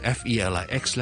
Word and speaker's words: Felix: F E L I X 咧。第一个 syllable 是 E 音嚟嘅，所以咧系紧少少嘅。